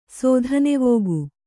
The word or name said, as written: ಸೋಧನೆವೋಗು